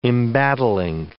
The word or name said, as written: embattling